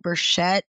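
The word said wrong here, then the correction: Beshert (באַשערט): am I saying that right? – Beshert. – Yeah.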